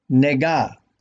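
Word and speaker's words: Nega (נגע): The word for plague in Hebrew is spelled Nun Gimel Ayin, read right to left, and is pronounced Nega.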